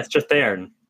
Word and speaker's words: Strathairn: Uh, first of all, I can't believe that you mispronounced King David Strathairn's name. I can't believe you did that. – Strathairn? – Yeah, Strathairn.